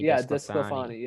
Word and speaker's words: DeSclafani: Discalfini. Discalfani. Discalfani. Anthony Desclafani. – Yeah, that's how you say it.